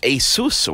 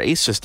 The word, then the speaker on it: ASUS: but ASUS or ASUS, depending on how you want to pronounce that, have uh, have been sending us some very cool product.